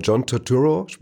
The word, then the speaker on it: John Turturro: es ist von, da spielt, wie heißt, ich weiß den Namen nicht mal, John Turturro, wird er so ausgesprochen?